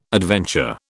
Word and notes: adventure: British English

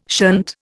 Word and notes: shunt: Indian

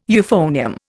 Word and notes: euphonium: Indian

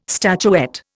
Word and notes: statuette: Indian

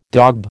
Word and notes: daub: American English

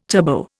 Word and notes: turbo: Indian

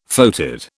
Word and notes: foetid: British English

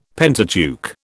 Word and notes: Pentateuch: British English

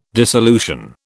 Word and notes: dissolution: British English

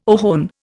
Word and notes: ohone: Indian

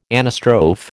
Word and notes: anastrophe: American English

